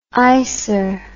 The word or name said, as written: icer